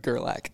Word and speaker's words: Gerlach: No, how do I say that? – Gerlach. – Dang, that's close. – Not even close. – Not even close. – Okay. Same thing, only different. – Gerlach. Looks like Gerlich to me.